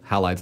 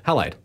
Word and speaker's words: Halide: If you like your Instagram photography with a little bit of depth of field, Halide's the way to go. – Halide. – Halide. – How do you spell that again? – H-A-L-I-D-E. Halide. – Oh, that's easier than I thought.